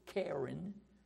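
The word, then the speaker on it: qeren: Now, the Hebrew word for horn is K-E-H-R-E-N. That's the pronunciation of it, Karen. It's spelled with a Q, Q-E-R-E-N.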